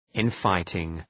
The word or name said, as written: infighting